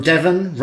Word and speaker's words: Devon: From Devon, rhymes with heaven.